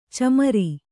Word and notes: ಚಮರಿ: camari

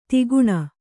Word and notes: ಧೊಕ್ಕನೆ: dhokkane; Kannada

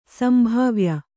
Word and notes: ಸಂಭಾವ್ಯ: sambhāvya

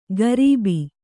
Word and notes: ಗರೀಬಿ: Kannada

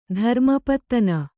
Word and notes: ಧರ್ಮಪತ್ತನ: Kannada

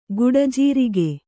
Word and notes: ಗುಡಜೀರಿಗೆ: guḍa jīrige

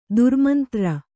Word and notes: ದುರ್ಮಂತ್ರ: durmantra

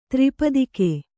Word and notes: ತ್ರಿಪತಾಕ: tri patāka

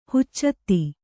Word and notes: ಹಚ್ಚೊತ್ತಿ: haccotti

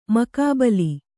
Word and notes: ಮುಖಾಬಿಲೆ: mukhābile

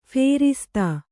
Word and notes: ಫೇರಿಸ್ತು: phēristu

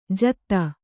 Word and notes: ಜಾದೂ: Kannada

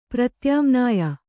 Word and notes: ಪ್ರತ್ಯಾಮ್ನಾಯ: pratyāmnāya

